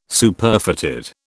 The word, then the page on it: Superfatted: British English